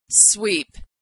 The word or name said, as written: sweep